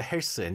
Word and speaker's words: 헬스: I'm going to go to 헬스. there's no TH pronunciation, so it's like 스.